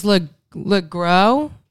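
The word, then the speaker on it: Le Gros: James LeGrow.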